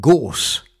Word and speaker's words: gorse: The second stanza of the poem is about gorse, which, of course, in British pronunciation rhymes with the word force of the opening line.